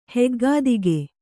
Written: ಹೆಗ್ಗಾದಿಗೆ

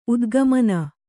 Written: ಊದುಗಾಮನಿ

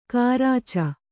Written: ಕಾರಾಚ

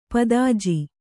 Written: ಪದಾಜಿ

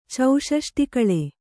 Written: ಚೌಷಷ್ಟಿಕಳೆ

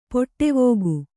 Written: ಪಡಿವಾಘೆ

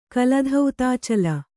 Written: ಕಲಧೌತಾಚಲ

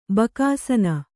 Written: ಭಕ್ಷಣ

